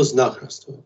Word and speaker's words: знахарство: Как правильно это произносится слово? – Ну, насколько мне довелось определить, именно что знахарство, потому что это тот же самый корень, что и в слове «знать».